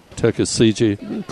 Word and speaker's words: Tuckasegee: Tuckasegee, Corolla.